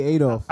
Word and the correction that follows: Althoff: Althoff. – I can't stand. – I don't know how you say it. – Whatever her name is. – Bobby Althoff. – Yeah, Bobby Althoff.